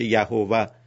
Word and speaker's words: یهوه: در متون یهودیت خدا رو با نامی میخوانن که از باب اینکه خود اینها تلفظ این نام را هم مجاز نمیدونن، در تلفظش هم با هم اختلاف دارن، ولی یکی از معروفترین تلفظها این است که یهوه، خدا را مثلا با نام یهوه میشناسن.